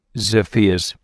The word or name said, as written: Xiphias